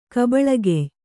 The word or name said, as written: ಕಬಳಗೆಯ್